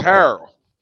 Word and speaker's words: Harrell: And it's Harrell. Montrezl Harrell. Not Andre Harrell. We don't pronounce it like him.